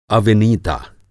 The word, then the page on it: अविनीता: Hindi